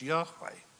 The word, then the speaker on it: Yahweh: In English, those are transliterated as YHWH, Yahweh. Really, as we said before, if you think about it, Yahweh's name, if you don't insert the vowels for our convenience, Jehovah, go back to Yahweh, think about it with me.